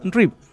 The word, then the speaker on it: Reeve: Uno es Reeves y el otro es Reeb. – Sí. – Tienen una S de diferencia.